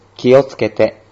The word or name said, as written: kiotsukete